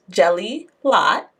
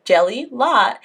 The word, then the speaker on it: Jelilat: My name is pronounced Jelilat. If you split my name down the middle there, Jelilat, it's easy to remember.